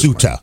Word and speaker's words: Ceuta: So that's Ceuta.